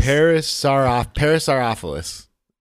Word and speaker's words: Parasaurolophus: That's the one that has Parasaurolophus.